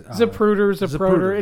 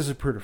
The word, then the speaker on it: Zapruder: Zapruder. It's an app, tomato. – If you look at the Zapruder film, right? – I think you said it right that time. – Did I? I'll fuck it up again. – Okay, later. – All right, okay. – So if you look at the Zapruder film, I said it right again.